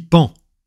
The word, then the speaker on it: paon: On dit pan, pas paon, malgré la façon de l'écrire.